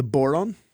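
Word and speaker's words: bodhrán: Well, I brought the Irish drum, which is called the boron. Conveniently rhymes with moron. – Oh, but it's spelled B-O-D-H-R-A-N. – Because I call it the Bodran. Yeah, so the I'm wrong.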